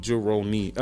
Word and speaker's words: Jeroni: But JUHROWNEE Jeroni, and that's how you pronounce my name, spelled differently because you know Dominican moms.